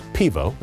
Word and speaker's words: pivo: Beer, by the way, in Slovenian is pronounced pivo.